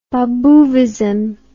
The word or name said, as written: Babouvism